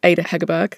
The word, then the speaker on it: Ada Hegerberg: I think my player to watch is Ada Hegerberg, because obviously it's the first time that she's been playing in a national tournament for a long time.